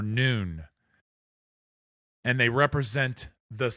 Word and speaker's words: Nun: Now, let's go to the slide that says N-U-N, nun, or noon. And I think people should pronounce it noon and I'm going to explain why.